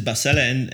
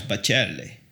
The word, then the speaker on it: Bacelle: My surname, they pronounce it Bacelle in Belgium. In Italy, is of course Bacelle. – Bacelle. – Bacelle. So it's a little bit different, but it's the same.